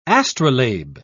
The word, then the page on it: astrolabe: British English